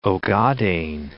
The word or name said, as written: Ogaden